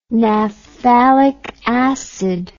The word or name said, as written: naphthalic acid